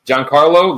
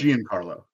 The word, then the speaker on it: Giancarlo: Giancarlo. – Yeah. – Not Giancarlo. – But, whatever, that's what I say. Giancarlo. – It's not John, it's Gian.